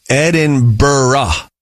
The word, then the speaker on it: Edinburgh: And my truth is, no, it says do say Edinburgh. Do not say Edinburgh.